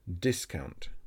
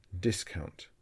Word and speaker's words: discount: This word has the stress on the first syllable. Discount. Discount.